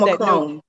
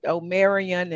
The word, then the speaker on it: Omicron: How do you pronounce Omicron. – That? Omicron. Okay, I've called it Omarion and so many other things.